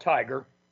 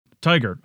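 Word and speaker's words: TIGHAR: And the acronym for the group, is it TIGAR is how you pronounce it? – We call it TIGAR, like go get 'em. – TIGAR.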